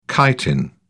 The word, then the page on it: chitin: British English